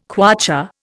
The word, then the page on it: kwacha: Indian